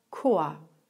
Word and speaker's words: Chor: Auch dann kann es wie K ausgesprochen werden. Zum Beispiel bei dem Wort Chor.